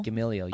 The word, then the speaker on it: Gamaliel: Gamaliel. – Gamaliel.